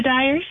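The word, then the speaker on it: Diers: You got, is it the Deers or the Dyers? How do you say that? – You know what? I am not 100% sure. I pronounce it the Dyers. – Okay. – I hope that's correct, and I'm sure they will let me know on Saturday. – Yep. It's D-I-E-R-S.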